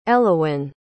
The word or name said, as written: Elowyn